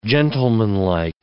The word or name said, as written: gentlemanlike